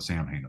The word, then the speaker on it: Samhain: So I think that was more about just stop trying to get people to pronounce it the way he pronounced it correctly. Cause the average person is going to read that as Sam Hain. Yeah. And so he gave up on that, but hence it's called Sam Hain. But in all the songs, he pronounces it Samhain.